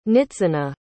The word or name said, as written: nitzanah